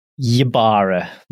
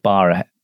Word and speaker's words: Ybarra: Not a silent Y, believe it or not. – Why? I don't know why it would be. That'd make it completely different. – Yes, it would. That would be Mike Barra.